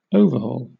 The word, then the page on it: overhaul: British English